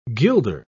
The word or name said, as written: gilder